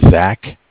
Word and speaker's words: Zach: My name is Zach, spelled Z-A-C-H. – Okay. – From Bank of Cardiff. And Cardiff is spelled C-A-R-D-I-F-F.